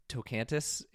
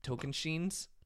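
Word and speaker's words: Tocantins: Survivor 18, I said Tocantis. It is Tokenshins. – Tokenshins? I was always Tocantis. Okay. – Yeah, exactly. I don't know. I always look at that one and I read it phonetically, and it is not.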